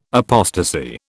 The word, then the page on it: apostasy: British English